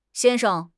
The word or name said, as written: xian1 sheng5